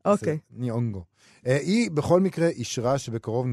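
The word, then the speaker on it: Nyong'o: אה, השחקנית המקסימה, לופיטה ניוגו, יונג, יונגו. – ניונגו. ניונגו, אבל לא מבטאים את הנון בהתחלה בשמות אה, אפריקאים לדעתי.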